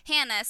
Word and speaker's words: Heina: What if his reference was to the word Hannah, spelled H-E-I-N-A, pronounced the same?